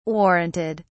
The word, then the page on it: warranted: British English